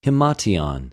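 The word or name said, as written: ἱμάτιον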